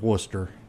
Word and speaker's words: Worcester: He goes, I've heard of Worcester. And I was like, wow, number one, you said it right.